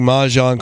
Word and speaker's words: Mahjong: By the way, we were pronouncing Mahjong correctly.